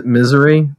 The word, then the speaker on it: Missouri: I'm pronouncing that right. Yeah, correctly, yeah, right? – For sure. – Missouri? That's the state name.